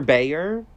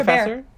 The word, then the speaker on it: Bayer: Professor Bayer. Is that how you say his name? You're like, no. Okay, who? So how do you say it? – My hair? What? – Professor? – Professor? Bear. Like, bear.